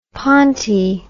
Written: ponty

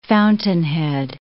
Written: fountainhead

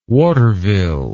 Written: waterville

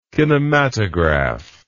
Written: kinematograph

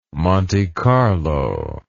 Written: Monte Carlo

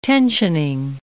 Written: tensioning

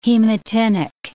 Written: hematinic

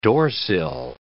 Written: doorsill

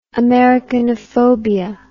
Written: americanophobia

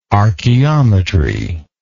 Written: archeometrist

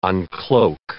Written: uncloak

